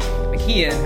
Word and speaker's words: McKeon: I know even Coach Harbaugh got it wrong a little bit, but it's Irish, so it's pronounced McKeon.